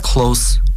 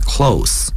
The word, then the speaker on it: close: Is that close to Highland Shopping Center? Is that close? 应该读成 close 而不是 close，因为 close 是动词， close 在这里是形容词。